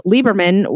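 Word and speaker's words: Liberman: My name is spelled M-E-L-I-S-A with one S. And my last name is spelled Lieberman without an extra E. So it's L-I-B-E-R-M-A-N.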